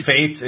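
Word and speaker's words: FATE: I'm pretty sure it's pronounced fates.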